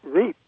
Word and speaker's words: route: I mean, I learned my English many years ago, perhaps decades ago, in various countries, and I do pronounce it root. So I hate to see root get routed, especially with students who are now looking at me with big question marks over their heads.